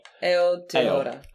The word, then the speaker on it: Aotearoa: Aotearoa? – I mean, it's pretty close. I mean, the first syllable, ale. – Aotearoa.